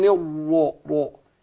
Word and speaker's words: الرجيم: والجيم ما انطقهاش معطشة كده بالشكل ده. هي معطشة. لكن كتير يعني من الأخطاء الشائعة في نطق الجيم إن كثير من الناس بينطقها إيه؟ زي الشين. يقول إيه؟ أعوذ بالله من الشيطان الرجيم. دخل على الإيه؟ على الشين. ما ينفعش.